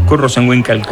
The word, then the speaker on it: Kurt Rosenwinkel: Oye, viene el Kurt Rosenwinkel. – Kurt Rosenwinkel Quartet, sí. – Sí. Tú has hablado con él y se pronuncia así. – Ah, ¿no? – Sí, como lo he dicho yo, exactamente.